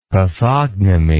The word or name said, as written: pathognomy